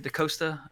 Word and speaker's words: DaCosta: I'm not sure how she pronounced it. DaCosta. Is it DaCosta? – Yeah, that's right.